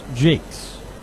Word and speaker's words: Jacques: Joe Jakes, spelled J-A-C-Q-U-E-S. Looks like Jacques, but it's pronounced Jakes.